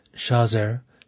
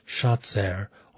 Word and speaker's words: Shazer: would have pronounced it, Shazer, Shatzer, or Shadzer.